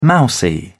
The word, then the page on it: mousy: British English